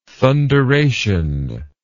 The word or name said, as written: thunderation